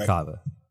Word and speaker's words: Karuna Kava: Is it Karuna Kava? – That's correct. Yep, that's right. – Karuna Kava.